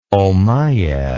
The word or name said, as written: ommiad